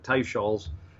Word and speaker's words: Teicholz: And I don't mean to have a vendetta to bring down Nina Teichel's, or she pronounces it Teichel's, a soft C-H, author of Big Fat Surprise.